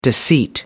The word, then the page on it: deceit: American English